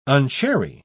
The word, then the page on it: unchary: British English